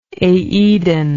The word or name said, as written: aedon